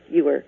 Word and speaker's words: Ewer: The spelling of our name is complicated and is pronounced Ewer. Lots of people get it wrong the first time.